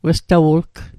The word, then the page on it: westawu'lgw: Mi'gmaq